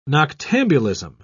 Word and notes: noctambulism: British English

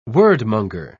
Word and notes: wordmonger: British English

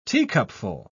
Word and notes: teacupful: British English

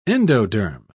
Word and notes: endoderm: British English

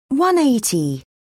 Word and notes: 180: British English

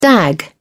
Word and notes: dag: British English

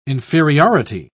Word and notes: inferiority: British English

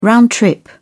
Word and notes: round trip: British English

